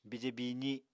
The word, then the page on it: bije biizi: Apatani